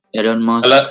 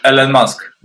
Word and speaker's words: Elon Musk: Elon Musk. Elon Musk.